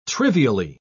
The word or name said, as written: trivially